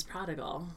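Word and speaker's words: Prah'd'gul: Oh, his name is Prodigal. – All right, are you being serious? – When I read it, I like... – You are being serious! – When I read it, I skipped over the You're name stuck. Entirely because it's spelled P-R-A-H apostrophe D apostrophe G-U-L.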